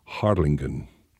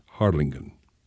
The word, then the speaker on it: Harlingen: It's pronounced differently, though. The Harlingen in Holland has a different G, Harlingen. That's the way they say it there, Harlingen.